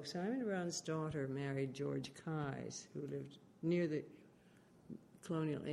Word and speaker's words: Keyes: Spell it. – K-E-Y-E-S. And yeah. – Pronounced Keyes? – Keyes, yeah.